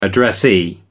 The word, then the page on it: addressee: American English